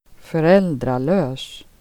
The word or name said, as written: lösa